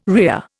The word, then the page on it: rhea: Indian